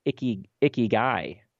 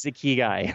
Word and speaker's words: ikigai: Well, a couple, I was on tour and a guy comes up afterwards and he says, you know, I speak Japanese and it's actually pronounced ikigai. And I was like, yeah. And I speak English and I'm not going to say ikigai because that just doesn't sound right. So it is ikigai.